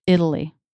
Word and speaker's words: Italy: But then a similar word, Italy. How do you hear the T there? Italy. – D. – Right. It's a flap. It comes between two vowels, and it doesn't start a stressed syllable. Flap T. – Even though Italian is... – Yeah, that's different. – True T. – That's different because there it starts a stressed syllable. – Mm-hmm. – Italian. Italy. Stress matters. – Mm-hmm. – Mm-hmm. Stress really affects pronunciation.